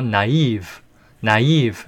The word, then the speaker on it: naive: 자, 제가 발음을 나이브라고 하지 않고 naive, naive 이렇게 발음을 하네요.